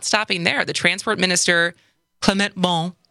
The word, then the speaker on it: Clément Beaune: Clément Beaune.